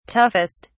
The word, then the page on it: toughest: British English